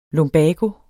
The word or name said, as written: lumbago